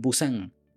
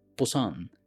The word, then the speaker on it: Busan: Lin es una desarrolladora web surcoreana que nació en Busan, bueno, que en realidad se pronuncia Busan,